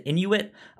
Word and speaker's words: Inuit: How Jordan's mechanic was in how do you say that? Is it Inuit? – Yes.